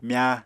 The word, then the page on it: mya: Apatani